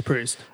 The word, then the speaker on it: Proust: He's reading directly from Remembrance of Things Past by um, Proust, is that how you pronounce it? Proust? Proust? I think it's Proust.